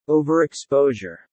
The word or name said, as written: overexposure